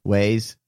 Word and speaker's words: Waze: Waze for traffic. Waze. You never heard of Waze? It's a... – Oh, W-A-Z-E.